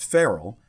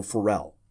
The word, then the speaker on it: Farel: Now, some pronounce that Farrell, but we're going to go with a more traditional Farel.